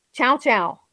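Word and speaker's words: Llao Llao: in Chow Chow, which is spelled LLAO-LLAO.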